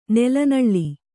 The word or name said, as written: ನೆಲನಳ್ಳಿ